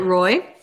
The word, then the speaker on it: Roy: To be fair, Patrick Waugh is the only guy that goes with the Waugh thing. Everyone else is Roy.